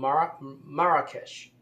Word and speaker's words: Marrakesh: And that's how the locals pronounce it. I think that's what I read somewhere. Marrakesh. And MRK, America, Marrakesh. You just add an A at the beginning, Marrakesh. It just sounds like America.